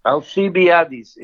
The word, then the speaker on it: Alcibiades: And like, if you want to speak of history, I often think that the Zelensky is like Alcibiades, or however you pronounce it in English. – Alcibiades.